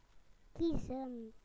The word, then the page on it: to choose: British English